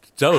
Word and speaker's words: Doge: Doge. How do you pronounce that? Yeah, is it Doge? Is that really? – Yeah.